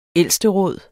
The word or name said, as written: ældsteråd